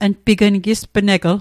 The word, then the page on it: gispneg: Mi'gmaq